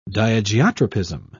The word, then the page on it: diageotropism: British English